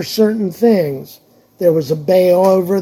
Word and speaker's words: Baal: It's actually pronounced Baal, even though it's B-A-A-L.